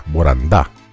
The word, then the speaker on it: Borandá: Boranda en portugués se pronuncia con acento en la A, Borandá.